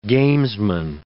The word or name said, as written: gamesman